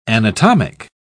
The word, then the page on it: anatomic: British English